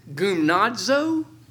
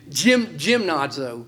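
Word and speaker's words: gymnazo (γυμνάζω): Interesting, the word exercise, and I looked up this word in the Greek, and just for Doug's help, it's spelled like gymnazo, G-Y-M. We get the word gymnasium from this, where you exercise. You pronounce it gymnazo. Did I say that right? – Yeah. – I wrote it out. I would have said gymnazo, because it's G-Y-M, but the Greeks is goom.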